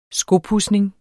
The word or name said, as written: skopudsning